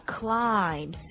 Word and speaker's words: climb: 其实英文里面有很多词啊，是后面，英文里面有很多类似的词啊，比如说 climb, C-L-I-M-B, 还有 C-O-M-B, comb, 梳头的梳子。啊，像这些词好像它有一个发音规律啊，在这个字母 M 后面的另外一个字母呢，就不发音，比如说 damn, climb, comb, 啊，后面的这个其实最后一个 M 后面的这个字的这个音呢没有不发音的，所以我们听到最多的就是 damn it, damn it, 该死的啊。